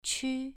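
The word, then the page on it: qu: tone 2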